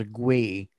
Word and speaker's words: gwei: So gas fees in Ethereum, they call it I pronounce it as a GWEI, G-W-E-I, right?